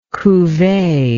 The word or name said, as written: cuvee